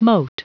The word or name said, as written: mote